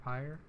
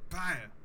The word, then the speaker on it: pyre: Book of the Amber Pyre? – Book of the Amber Pyre. Pyre. With a P. – Pie, sir? – P-Y-R-E. Pyre. – Oh, pyre. – Rhymes with fire.